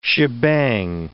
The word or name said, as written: shebang